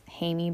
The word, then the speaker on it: Jaime: His name was Jaime, I think is how you say it in Spain. But spelled like Jamie, but pronounced Jaime.